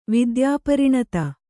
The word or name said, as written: ವಿದ್ಯಾಪರಿಣತ